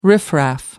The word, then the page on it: riffraff: British English